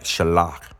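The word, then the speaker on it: shalach (שָׁלַח): Now, this word, send out of her house, If you look it up in the Hebrew word, this word is spelled S-A-L-A-H. S-A-L-A-H, and it's pronounced like shalach.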